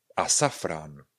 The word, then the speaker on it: azafrán: Nebo asafran. Asafran šafrán a safrán. Píšeme azafrán, a asafrán.